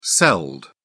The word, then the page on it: celled: British English